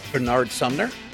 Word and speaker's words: Bernard Sumner: Even Bernard Sumner himself, he decided to write that song when he decided, ooh, I wanted Bernard Sumner. – Yeah, there we go. – Did I say his name wrong the first time? – No.